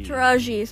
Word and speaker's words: Taraji: I don't know if I could see Regina King, but I could see the other one, the Tarani. Is that how you say her name? – Taraji.